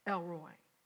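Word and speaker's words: El Roi: Now, you can put it up, Elroi, L-R-O-I. Now, the old church call it Elroi. The correct pronunciation is Roy, Elroi.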